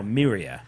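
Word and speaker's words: Mriya: Or Miria. I think you'd pronounce that. Miria.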